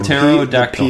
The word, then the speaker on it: pterodactyl: Pterodactyl is how I would pterodactyl. – The P is silent.